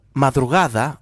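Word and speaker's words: Madrugada: Μια επανέκδοση του πρώτου άλμπουμ των Μαδρουγάδα, όπως προφέρεται σωστά, το Industrial Silence, δισκάρα, εντάξει, να λέμε τώρα, δεν υπάρχουν λόγια.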